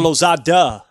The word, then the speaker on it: Lozada: Angelo Lozada.